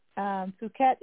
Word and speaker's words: Phuket: Yes, it's just because they're going, I'm sending them from Bangkok to the one that killed me, and I don't know how you pronounce it, but it sounds like Phuket. It's Phuket. Phuket, that's where I was at.